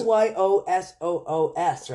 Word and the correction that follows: Osoyoos: It says here Osoyoos. – Osoyoos.